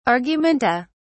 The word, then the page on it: argumenta: British English